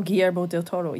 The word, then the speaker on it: Guillermo del Toro: Oh, Guillermo del Toro.